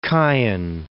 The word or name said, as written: Chian